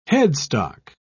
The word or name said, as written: headstock